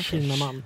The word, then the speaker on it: Kinnaman: Hur uttalar man det? Kinnaman eller Kinnaman? – Jag säger Kin Kinnaman. Kinnaman ja.